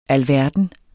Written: alverden

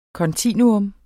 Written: kontinuum

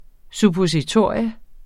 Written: suppositorium

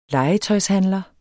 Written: legetøjshandler

